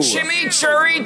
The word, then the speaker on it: chimichurri: Introducing Wendy's new chimichurri cheeseburger.